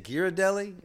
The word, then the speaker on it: Ghirardelli: I bought this little blue, is it Ghirardelli? Is that how you say it? – Oh yeah.